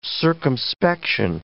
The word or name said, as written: circumspection